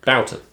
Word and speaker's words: Boughton: And when we do that, we tend to go down and meet, how do you pronounce his surname? Boughton. – Doesn't matter, who cares? – Rich Boughton. – He won't care. Anyway, he's Rich from Boughton. Kite Barn. – Boughton. Boughton.